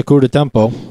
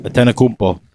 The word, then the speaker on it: Antetokounmpo: Say his name right. – Giannis Antetokounmpo. – Antetokounmpo.